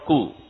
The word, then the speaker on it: coup: That's called coup. C-O-U-P. – C-O-U-P. Coup under. And in Africa, they say that they are going to do it. P is silent. Coup.